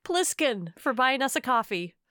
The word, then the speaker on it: Ko-fi: Yeah, it's spelled K-O-F-I. It's a little misleading. – Yeah. – We say coffee.